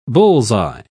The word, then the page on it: bull's-eye: British English